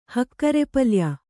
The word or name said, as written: ಹಕ್ಕರೆಪಲ್ಯ